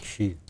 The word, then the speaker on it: Xi: Xi Jinping. – Şi de diyebilirsiniz. – Şi.